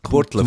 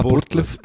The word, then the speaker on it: Burgdorf: kommen wir nach Burgdorf. – In die Burtlöf, Kultur, sagt man. Burtlöf.